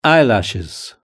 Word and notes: eyelashes: British English

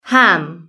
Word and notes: ham: British English

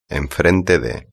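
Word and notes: enfrente de: Spanish (Spain)

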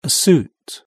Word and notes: a suit: British English

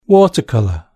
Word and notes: watercolour: British English

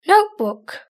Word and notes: notebook: British English

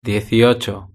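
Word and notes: dieciocho: European Spanish

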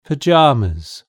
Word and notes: pyjamas: British English

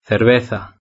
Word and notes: cerveza: Spain Spanish

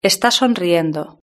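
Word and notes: esta sonriendo: Spanish (Spain)

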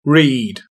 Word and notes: read: British English

